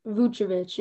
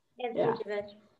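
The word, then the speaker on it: Vučević: But Vucevic, yeah. – Vucevic.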